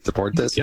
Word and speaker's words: Deportes: Fox Sports is airing a whole bunch of games, and Fox Deportes is also, I think that's how you pronounce it, right? Deportes? – Yeah, yeah. – They're airing a lot of games,